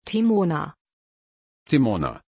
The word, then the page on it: timona: German